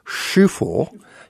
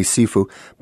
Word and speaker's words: Sifu: Well, you know, Sifu, or mostly is probably pronounced Shufu. Yeah, if you want to sound it more Chinese sounding. Okay. Americans say Sifu.